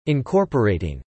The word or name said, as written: incorporating